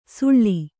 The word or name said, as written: ಸುಳ್ಳಿ